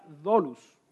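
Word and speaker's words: doulos (δοῦλος): Y entonces hablando del versículo, los versículos del día de hoy, esta palabra esclavo en griego se pronuncia doulos.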